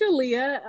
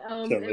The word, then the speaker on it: Shalia: Yes, I mean, you put an extra emphasis on that Sha, but it's Shalia. Shalia. And what I like to tell people is that it rhymes with Aaliyah. And okay. That kind of helps to cement with the word association.